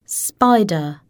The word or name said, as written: spider